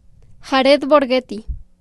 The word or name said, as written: Jared Borgetti